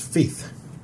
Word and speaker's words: Feith: We have found evidence of WMD programs, but we have a long way to go before we can understand them, says Douglas Feith. Rhymes with thief. Douglas, our defense official, Douglas Feith.